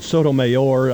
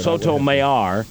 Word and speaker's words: Sotomayor: And the guy on NBC pronounced it different. – Mayor, he said Mayor. – Mayor? – Yeah, he said Sotomayor. – That's probably more accurate, I would imagine.